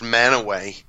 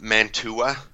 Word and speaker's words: Mantua: It's pronounced Manaway, but it's spelled out Mantua. Mm-hmm. So, you know, if anybody's not from here, they're like, I'm looking for Mantua. And right. – You kind of laugh. You're like, nah, it's called Manaway.